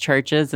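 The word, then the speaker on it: CHVRCHES: That's so confusing because I know it's pronounced Churches.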